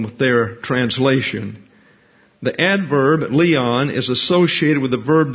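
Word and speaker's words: Leon (λίαν): Now, this is not Leon like a guy's name. That's L-E-O-N. This is L-I-A-N, pronounced Leon. Leon spelled backwards is Noel. But this is not Noel or Leon, this is Leon.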